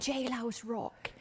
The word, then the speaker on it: Jailhouse Rock: Ja, Jailhouse Rock.